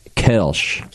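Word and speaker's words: Kölsch: This one I actually can never get right, but Kolsch in English is something like Kirsch.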